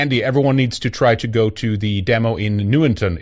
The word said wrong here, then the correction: Nuneaton: Is that how you say it? Newington? I saw it up on the... – Nuneaton. – Nuneaton. There you go.